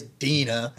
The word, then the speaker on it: Dena: D-E-N-A is Dina. If it was D-E-N-N-A, that'd be Dana.